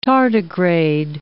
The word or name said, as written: tardigrade